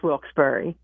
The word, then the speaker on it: Wilkes-Barre: I'm originally from Philly, born there, raised in Upper Darby, but I've been here for 17 years, so I learned how to say it. It's Wilkes-Barre.